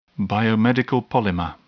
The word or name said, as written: biomedical polymer